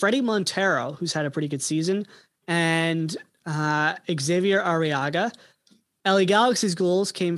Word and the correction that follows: Xavier: Javier. It's Javier. – Ooh. Oh, what? – It's Javier Arriaga. – It's an X. – Yes, and it is pronounced Javier.